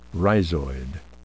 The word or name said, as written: rhizoid